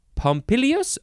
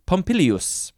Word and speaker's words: Pompilius: Pompilius. Oh, I said it wrong. There's another I in there. Pompilius.